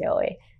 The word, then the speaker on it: yaoi: which I said completely wrong in the other video, so please ignore that, but I believe it's pronounced yaoi.